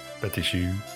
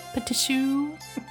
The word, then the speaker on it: petit chou: Petit chow. – Petit chou. – Petit chou. Pronounced it incorrectly that night.